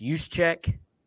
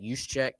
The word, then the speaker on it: Juszczyk: It's Juszczyk. – Yeah, that's how you say it, Juszczyk, even though it's not what it looks like.